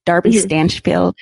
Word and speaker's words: Darby Stanchfield: That's how you pronounce her name. Darby Stanchfield from Scandal.